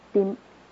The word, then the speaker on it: demoniac: Do you remember when he crossed the Sea of Galilee and healed the demonic, I can't say that word, demoniac, how do you say it, honey? Demoniac. Thank you.